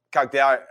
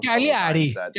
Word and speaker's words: Cagliari: The problem is Salernitana now have two games in hand against Cagliari. How do you pronounce it? I've said it wrong. – Cagliari. – Cagliari. Cagliari.